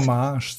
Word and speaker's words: Homage: Homage, Homage oder Hommage oder wie auch immer sie es aussprechen soll. Also dieses. Ich würde Klass Hommage sagen.